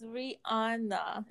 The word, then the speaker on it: Rihanna: It's Rihanna. Like Rihannuh. That's how you should say it.